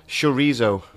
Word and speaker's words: chorizo: Chorizo. – Yep, but you don't say it like that. – Is it a th? – It's a sh. Chorizo. Sh.